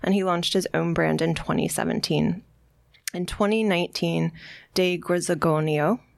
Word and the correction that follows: de Grisogono: did I say that right? – De Grisogono.